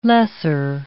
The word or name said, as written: lesser